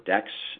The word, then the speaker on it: DECS: The Clean Coalition is working on one that we're designing that's called Dispatchable Energy Capacity Services, DECS, or DEX, as we pronounce it.